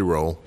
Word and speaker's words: Rolle: Or Rolle. I don't know how to say his name. – It's probably Roll. – It's Roll.